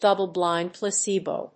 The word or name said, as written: double‐blind placebo